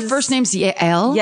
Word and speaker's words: Yael: I meant the Jewish name, Yale, Y-A-E-L. – Oh, yeah, like Yael. – Oh, is that how you pronounce it?